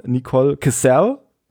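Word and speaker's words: Kassell: Wurde ähm, regiert von Nicole Cassell. So hörte ich, spricht man sie aus.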